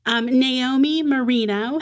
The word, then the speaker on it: Naomi Merino: Naomi Marino, did I say your name right? – Yeah, you did.